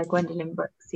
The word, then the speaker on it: Gwendolyn Brooks: And it's Gwendolyn Brooks, is it? Have I got that pronounced right? – Yeah, Gwendolyn Brooks, yeah.